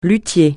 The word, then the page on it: luthier: francais